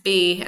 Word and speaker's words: Bey: Okay, so his title, even though he wasn't a prince, his title was pronounced Bey, B-E-Y. – Not which, Bey, like my Bey. – B-E-Y, is that not how you pronounce Bey?